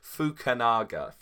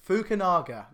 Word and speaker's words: Fukunaga: like Cary Joji Fukunaga. Oh, I can never do it. – Oh my God. – I can never do it. – Oh my God, you butchered that, didn't you? Fukunaga. – Fukunaga, yes.